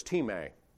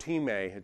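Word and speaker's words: timē (τιμή): That word timē, it's spelled time. If you ever want to know how to transliterate that, it's T-I-M-E, just like we'd spell time. It's timē.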